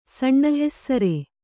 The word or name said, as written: ಸಣ್ಣಹೆಸ್ಸರೆ